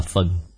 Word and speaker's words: phần: Ở đây nói là thể địch nhi phân, chỗ này nên đọc là phần.